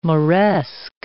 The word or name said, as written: moresque